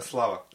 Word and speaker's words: Slava: Euh, par exemple, il y a une fête en Serbie, c'est la, je ne sais pas le prononcer, Svlata, comment on dit ça la, fête du Saint-Patron. Euh, euh, Slava. Slava.